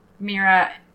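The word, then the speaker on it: Myrrha: Mira, it's pronounced Mira, and okay. But it's spelt like myrrh with an A on it.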